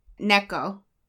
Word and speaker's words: Neko: I probably said the name wrong. It's Nico at something or other. But Nico means cat in Japanese? – Yes. – Okay. – Neko.